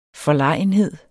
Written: forlegenhed

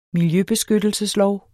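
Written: MILJØBESKYTTELSESLOV

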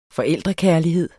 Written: forældrekærlighed

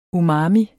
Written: UMAMI

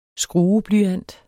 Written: skrueblyant

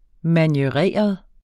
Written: manieret

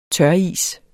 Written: tøris

